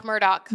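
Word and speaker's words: Murdaugh: Alex Murdoch.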